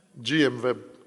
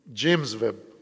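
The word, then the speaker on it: James Webb: جیمز ویب یا جی ایم ویب جیم ویب انہوں نے بھی کسی ڈولچی سے ہی سنا ہے میرے خیال صحیح تلفظ نہیں ہو رہا جی جیمز ویب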